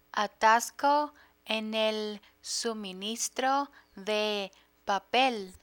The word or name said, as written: atasco en el suministro de papel